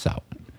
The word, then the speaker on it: Samhain: And it's spelled Samhain, but pronounced Samhain.